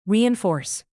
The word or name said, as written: reinforce